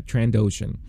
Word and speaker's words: Trandoshan: Trandoshan, right? Uh, I believe that's how you pronounce it. Um, it, eh, let me just make sure. Yeah, Trandoshan, um, and there are a lot of Trandoshans in Mos Espa, and po- more more possibly on Tatooine.